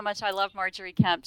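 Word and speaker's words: Margery Kempe: But in your book, we get a lot about Marjorie Kemp. Is that, that's the way to pronounce it? – It is.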